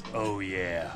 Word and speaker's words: Ouya: Ist es Ouya, Ouyeh, Oh Yeah? Wie spricht man das aus? – Wir ab jetzt Oh Yeah. – Oh Yeah. Okay, das machen wir so.